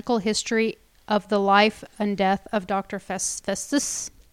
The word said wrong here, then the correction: Faustus: Festus or Faustus? – Faustus. I can't pronounce things.